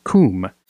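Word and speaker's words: kum (קום): The Hebrew word for arise is pronounced as kum and is a very common Old Testament word.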